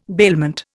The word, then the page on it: bailment: Indian